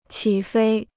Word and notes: 起飞: qǐ fēi

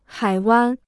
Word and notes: 海湾: hǎi wān

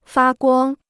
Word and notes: 发光: fā guāng

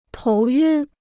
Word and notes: 头晕: tóu yūn

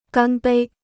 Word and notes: 干杯: gān bēi

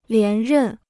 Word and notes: 连任: lián rèn

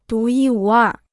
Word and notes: 独一无二: dú yī wú èr

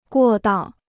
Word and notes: 过道: guò dào

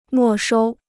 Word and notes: 没收: mò shōu

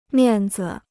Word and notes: 面子: miàn zi

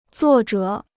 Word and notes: 作者: zuò zhě

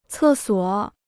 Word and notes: 厕所: cè suǒ

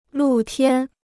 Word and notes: 露天: lù tiān

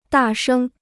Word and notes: 大声: dà shēng

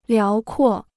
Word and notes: 辽阔: liáo kuò

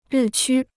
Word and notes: 日趋: rì qū

